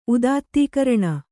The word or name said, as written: ಉದಾತ್ತೀಕರಣ